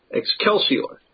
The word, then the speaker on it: Excelsior: No, I usually would even do a very classical Roman pronunciation of this would have been with a hard C as a K, Excelsior.